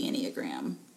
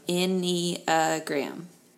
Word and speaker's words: Enneagram: So what is the Enneagram? – Yes, so going to break it down for you. Ennea means 9. Gram means like a drawing or a diagram. So Enneagram.